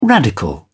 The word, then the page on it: radical: British English